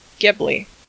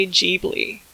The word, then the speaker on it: Ghibli: Now, when I saw John Lasseter give a speech back when the Studio Ghibli films were released by Disney, I heard him say Ghibli, and I said Ghibli then for like years. So now I kind of feel like a jackass, but I did look up the pronunciation and went to my old Blu-rays and found this little behind the scenes thing, an explanation by the Studio Ghibli folks, where they said, yeah, we took the name from Italy, but we pronounced it wrong, so we made up our own word. In Japan, it's Ghibli.